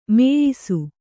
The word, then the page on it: ಮೀಯಿಸು: mīyisu